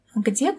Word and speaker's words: где: Because of the apostrophe, I'm not quite sure how to pronounce this. Где? – Где coffee table? It means, where is coffee table? – Yeah, где? – Где? – Mm-hmm. It's where.